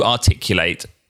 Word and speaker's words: articulate: Yeah, to articulate. and to be articulate. Now, what I've just realized as I've said those words is the spelling is identical, but you actually pronounce them differently.